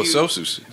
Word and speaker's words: Sosus: Sosus. – S-O-S-U-S, which is double IPA.